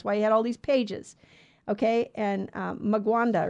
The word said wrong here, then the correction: Mwanga: or something, his name. I don't know how to say it. It's Mwanga, King Mwanga of Uganda.